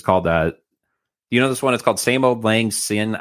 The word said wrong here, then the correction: Auld Lang Syne: I'm not even sure if I'm pronouncing it right. – Oh, Same Old Auld Lang Syne.